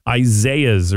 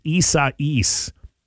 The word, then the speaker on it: Isaias: I literally called it Isaiah's or something like that. And it was Isaias.